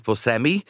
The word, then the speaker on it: Buscemi: This is the Hollywood star, Steve Buscemi. I think is how you pronounce his name.